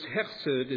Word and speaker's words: chesed: This chesed is shown, and you got to get that guttural sound in your throat to say it correctly there.